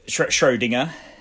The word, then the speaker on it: Schrödinger: Is that how you pronounce it? Schrodinger's? – Nearly. – Schrödinger. – Schrödinger.